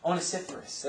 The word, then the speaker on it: Onesiphorus: Why is Onesimus here being mentioned? Or, I'm sorry, Onesiphorus. There we go.